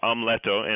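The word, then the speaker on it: Amleto: As you mentioned, you're presenting two full operas, Amuleto. Is that correct? Did I say that right? – Yes, we're calling it Hamlet? but you said it right. – Okay. – It is Amuleto in Italian.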